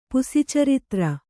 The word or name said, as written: ಪುಸಿಚರಿತ್ರ